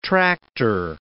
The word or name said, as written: tractor